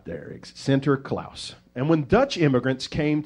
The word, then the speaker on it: Sinterklaas: And in the Dutch language, Saint Nicholas is Sinterklaas. That's how you say it in Dutch.